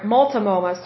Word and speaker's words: Multnomah: Next is Lane, Douglas, Lake County, Multnomah, Multnomah, Walla uh, um, Wasco, Multnomah, Clackamas, Waltanoma, Waltanoma, or sorry, Multnomah. Sorry, I'm getting my W's and my M's mixed up because these counties sound strange. Multnomah.